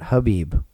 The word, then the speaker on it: Khabib: Khabib. – And I think it's actually, I think they've switched it up and they're pronouncing it Habib. I think they're dropping the K sound from the pronunciation.